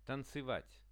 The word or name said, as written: tancevat